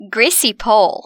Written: Greasy pole

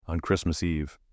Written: on Christmas Eve

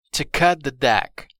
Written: to cut the deck

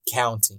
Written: counting

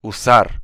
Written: usar

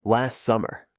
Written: last summer

